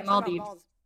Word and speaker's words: Maldives: Yeah, Maldives.